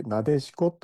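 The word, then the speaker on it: 撫子: 秋の七草の一つナデシコですけれど、ナデシコは漢字で手辺にないと書いてなでるという字、それから子供の子と書いてなでしこと読ませるんですが、これはなでたいほど可愛らしい子という意味だという説があるんです。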